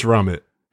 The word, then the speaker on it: Ramit: I have I Will Teach You to Be Rich by Ramit Sethi. – I'm going to call him Raymond. – No, I'm pretty sure it's Ramit. I'm like 92% sure.